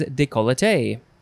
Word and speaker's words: décolleté: It is décolleté. Décolleté, décolleté, décolleté, décolleté. Yep. Or you can also, again, take off that extra syllable and say décolleté.